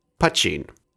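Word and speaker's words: poitín: While spelled P-O-I-T-I-N, with an accent on the second I, according to John Ralph, who is Irish, as well as creator of Mad March Hare, it's pronounced Putchin. Not to be confused with poutine, the Canadian French fries with gravy and cheese curds, though lovely, this is Putchin.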